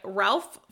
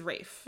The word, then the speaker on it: Ralph: Oh, you know what? I did see where he was like, yeah. One of the quotes that he always uses is, it's Ralph. – Yeah. It's Ralph Fiennes. – It's spelled Ralph. – He can say how he wants his name pronounced. – That is fine. But someone who is in trouble talking, it looks like it's Ralph. Anyways, his full name is Ralph Nathaniel Twilston Weakenham Fiennes.